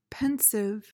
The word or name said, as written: pensive